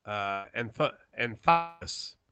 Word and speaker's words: Anthopoulos: But Alex Anthopoulos. Anthopoulos. Is that way? – Yeah, give that man a medal.